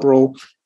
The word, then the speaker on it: Brulle: And so when you started approaching this this research you did with your co-author, Professor, I'm going to get his name, pronounce his name wrong, Brule, is that correct? – Yeah, that's correct, Professor Robert Brule.